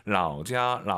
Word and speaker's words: 老家: 老家 발음이 똑같은 게 아니고 성조가 똑같은 거죠. 라오자 라오시 내가 먹어요.